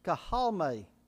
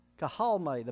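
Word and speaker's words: kahalme (καυχάομαι): But it's K-A-U-C-H-A-M-A-I. And it's pronounced like this, kahalme. Kahalme. The middle part's kind of silent. Kahalme.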